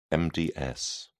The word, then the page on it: MDS: British English